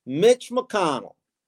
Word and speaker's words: Mitch McConnell: I almost accidentally mispronounced it with the second letter of the alphabet, but Mitch McConnell.